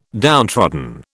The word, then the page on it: downtrodden: British English